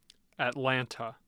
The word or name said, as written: atlanta